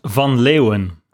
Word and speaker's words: van Leeuwen: Yes, it's Van Leeuwen.